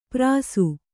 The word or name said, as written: ಪರಸು